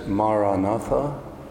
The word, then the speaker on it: Maranatha: And the word I suggest is Maranatha, four syllables. Ma-ra-na-tha.